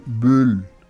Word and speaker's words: Böll: La pianista es Claudia Corona, acompañada por la Sinfónica de Nuremberg, dirigida por Gregor Böll. Así lo dice uno como ni U, ni O, ni E, Böll. Así. Así me dijeron que se pronunciaba.